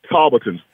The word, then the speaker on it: Talbotton: Was it Talbotton? You a native? Was that Talbotton or It's pronounced Talbotton.